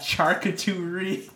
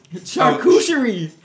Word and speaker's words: charcuterie: Charcuterie. – Charcuterie. – Charcuterie. – Charcuterie. – Charcuterie. – Yeah, so Charcuterie.